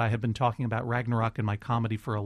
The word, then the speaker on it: Ragnarok: Ragnarok is how I pronounce it.